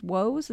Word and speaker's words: Woese: The father of this work is a guy named Carl, and I think his last name is Woese. Is that how you say it? – That's right, yeah.